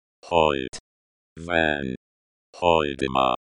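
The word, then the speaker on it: Hoyte van Hoytema: And the cinematography was created by Hoyt Van Hoyten. Shit. – English, motherfucker! – Yo, Lamebot, help me out real quick, because I can't say this dude's name. – It's pronounced Hoyt Van Hoytema.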